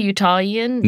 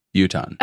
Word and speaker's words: Utahn: Wait, is that a thing? Utahian? – No, just Utahn. – Utahn? – U-T-A-H-N. Utahn. – Oh, it's a thing. All right. Utahn. – Yes, that's how you say it.